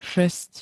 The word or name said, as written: šesť